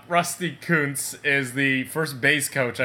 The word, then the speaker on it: Kuntz: Or, as a dumb American would pronounce it, it's K-U-N-T-Z.